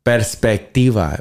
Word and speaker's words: perspectiva: Perspectiva was the word, I think. – Oh, we've been saying it wrong? – I think I said perspectivo. Yeah. Perspectivo. But I think everybody was correcting me in perspectiva.